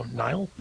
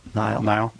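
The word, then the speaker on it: Niall: It's N-I-A-L-L. Is that pronounced Neil? I don't know. Nile? – Nile. Probably. I think he's British. Nile. It's probably Nile.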